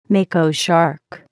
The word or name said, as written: mako shark